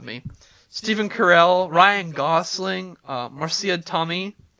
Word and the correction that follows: Tomei: Mercy Tomei, thank you, and, Rudy, I can't pronounce that last name.